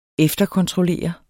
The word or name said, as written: efterkontrollere